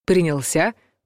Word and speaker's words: принялся: Ударение в глаголе «приняться» в прошедшем времени падает на последний слог. «Принялся», «принялась», «принялись» и так далее.